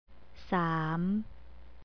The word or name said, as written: sam